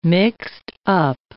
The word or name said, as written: mixed-up